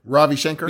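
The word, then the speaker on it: Ravi Shankar: How do you say his name? Robbie Shankar? – Yeah. – Yeah. – Mm-hmm. Robbie Shankar.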